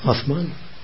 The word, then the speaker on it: Atman: The center of your being is Atman, the self. – A-T-H-M-A-N, Atman, and always capitalized in any translation.